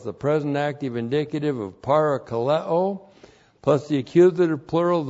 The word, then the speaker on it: parakaleo: Parakaleo, P-A-R-A-K-A-L-E, long O, parakaleo.